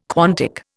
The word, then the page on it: quantic: Indian